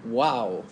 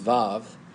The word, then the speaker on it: Vav: And tonight we're looking at the wow section of this psalm. That's not really how you pronounce the Hebrew letter. It's Vav, but it looks like wow in your Bible.